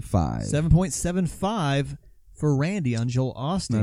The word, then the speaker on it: Osteen: I've heard Osteen a couple of times, but predominantly I've always heard Osteen. – Yeah. So I'm going to go Osteen. – I think that's the way that it's pronounced.